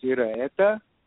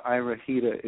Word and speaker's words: Iraheta: Well, there are multiple ways that it's pronounced. I think the correct pronunciation is Iraheta. But in America, Iraheta is fine.